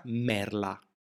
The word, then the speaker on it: merla: Si pronuncia merla, con la lettera L di limone.